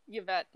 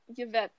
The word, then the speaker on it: Yvette: Yvette. – One more time? – Yvette. It's spelled Y-V-E-T-T-E. – Y-V-E-T-T-E.